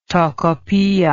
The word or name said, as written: Tocopilla